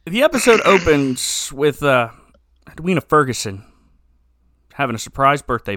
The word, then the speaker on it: Edwina: I don't think you're supposed to put that much emphasis on the we part of the name. Edwina? Edwina. – Edwina.